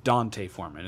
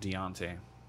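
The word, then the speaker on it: D'Onta Foreman: And, oh, yeah, apparently we've all been pronouncing his name wrong. It's Dante Foreman. It's not Deontay.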